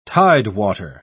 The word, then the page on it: tidewater: British English